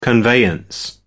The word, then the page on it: conveyance: American English